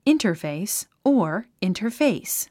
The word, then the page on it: interface: ìntə r féis; インタァフェイス